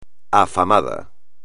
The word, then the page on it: afamado: espagnol